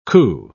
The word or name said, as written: coo